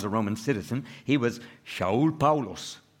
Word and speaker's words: Shaul: His Hebrew name, Saul, in Hebrew pronounced Shaul.